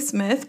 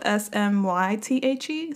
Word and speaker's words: Smythe: I almost want to say Smith, but it's spelled Smythe, so I'm going to say Smythe.